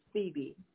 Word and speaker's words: Phoebe: Say it again. – Yeah, that's how you pronounce that name, is Phoebe.